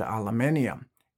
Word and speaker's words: aluminium: Two of the most important metals for green electrification are copper and aluminium. Yes, that's how it's pronounced, and it's also how it's spelled.